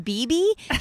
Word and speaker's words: bebe: And Carrie goes, you mean BB? She goes, I always said it wrong. Oh. I always said Bebe.